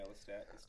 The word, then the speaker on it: Lestat: Yeah, Lestat.